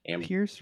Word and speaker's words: Pierce: Pierce rhymes with fierce.